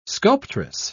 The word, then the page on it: sculptress: British English